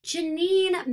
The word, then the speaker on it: Janine: I think her name rhymes with Smineen. – Oh my God, that's perfect. Yes, Janine made perfect sense.